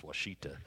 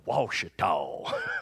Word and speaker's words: Ouachita: And I found out that means good hunting in Cheyenne Arapaho language, Ouachita, and that's how you pronounce it, it's Ouachita. Instead of, we go, Ouachita. That's what we say, you know.